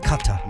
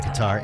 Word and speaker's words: Qatar: Is that how they say it over there? – That's how they say it. Qatar. – Americans say Qatar.